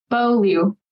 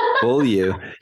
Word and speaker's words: Beaulieu: or how do you say Beaulieu. Beaulieu. – Beaulieu. Yeah.